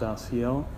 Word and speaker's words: Asiel: I am Brother Asiel. It's pronounced Asiel, A-S-I-E-L.